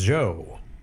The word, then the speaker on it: Djo: DJO, otherwise pronounced as Joe.